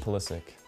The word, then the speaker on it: Pulisic: I'm Christian Pulisic.